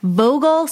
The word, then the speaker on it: Vogel: All right, Vogel State Park.